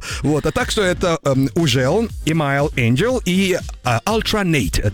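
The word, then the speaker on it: HUGEL: Трек называется «Ужел», а кто-то говорит «Хюгел». – Как мы его только не называли в свое время. «Ужел», «Хюгел», да? «Хюгель». – Самое правильное произношение – это «Ужел». Он француз.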